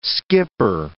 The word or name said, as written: skipper